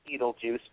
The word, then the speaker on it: Betelgeuse: It's pronounced Beetlejuice, but it's spelled B-E-T-E-L-G-E-U-S-E. B-E-T-E-L-G-E-U-S-E.